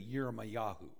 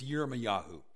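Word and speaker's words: Yirmiyahu (ירמיהו): In the in Hebrew, there's no J in the Hebrew alphabet, and so it's essentially Yirmiyahu. That's the how you pronounce Jeremiah in excuse me. In Hebrew, it's Yirmiyahu, and there's great debate what that exactly means.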